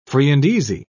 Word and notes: free-and-easy: British English

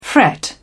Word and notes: fret: British English

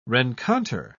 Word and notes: rencontre: British English

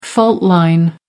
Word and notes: fault line: American English

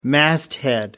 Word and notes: masthead: American English

